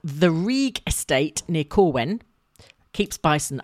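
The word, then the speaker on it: Rhug: As in R-E-E. – It's R-H-U-G, but you pronounce it Rieg.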